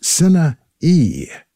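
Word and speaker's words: Sinne Eeg: Opening the set, music from a band called San Gabriel 7, along with vocalist Sinna E. That is S-I-N-N-E, last name E-E-G, pronounced E, Sinna E, from the album by the San Gabriel 7 called Under the Stars.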